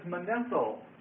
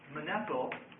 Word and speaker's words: Manetho: His name is Manetho. Manetho. And that's spelled M-A-N-E-T-H-O.